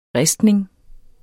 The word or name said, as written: ristning